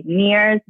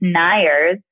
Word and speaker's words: Neiers: So my maiden last name is Niers, and it's spelled N-E-I-E-R-S. And everyone always say Niers, Niers, Niers. And I'm like, it's Niers.